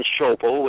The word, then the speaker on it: Schoeppel: My name is Kevin Schoepple, which is spelled S-C-H-O-E-P-P-E-L.